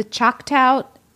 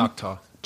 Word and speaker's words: Choctaw: At the height of the Irish potato famine, members of the Choctaw Nation, did I say that wrong? – Choctaw.